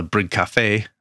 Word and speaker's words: bryggkaffe: It's called a bryggkaffe. I think I'm saying that correctly. – It sounds about right.